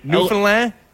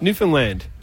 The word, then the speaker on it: Newfoundland: Am I overdoing it? Newfoundland? – Yeah, it's a little overdone. – settle down. – I'm gonna be honest with you. – Yes, please. – It's just Newfoundland. Understand Newfoundland.